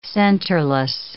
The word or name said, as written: centerless